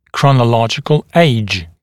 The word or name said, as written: chronological age